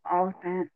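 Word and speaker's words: Olyphant: Oliphant, yeah.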